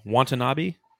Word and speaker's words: Watanabe: How do you say his last name? Watanabe? yeah. there you go.